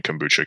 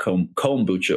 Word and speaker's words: kombucha: I think most people here would call it kombucha, like more emphasis on the O instead of the M, kombucha.